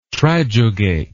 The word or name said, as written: trijugate